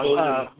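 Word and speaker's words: Bodē: Bode, yeah.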